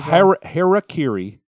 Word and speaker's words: Harakiri: And then this band, who you'll be able to hear through my voice that I'm smiling, Hakiri for the Sky. – Are we sure that's how it's pronounced? Is this another episode? – Harakiri, H-A-R-A. K-I-R-I, for the Sky, Harakiri.